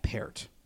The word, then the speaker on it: Peart: Someone that I watched and liked down at the Senior Bowl was the guy out of UConn, Matt Peart. – How do you spell that? – P-E-A-R-T. – Okay, not Peart. Like P-A-R-R-O-T.